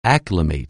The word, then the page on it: acclimate: anglais